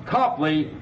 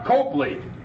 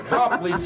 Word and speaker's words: Copley: All right, folks, we're ready to leave Copley Square, pronounced Copley, not Copley. After all, you wouldn't call a cop a cope, would you? So we say Copley Square.